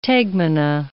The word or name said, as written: tegmina